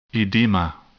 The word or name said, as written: edema